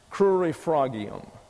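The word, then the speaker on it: crurifragium: You get a U, sort of a W sound in here, cru, and sort of a short I sound in the English, curi frog. And it's a, sort of an A-H, and that's your accent, and always a hard G in Latin, curi fragium.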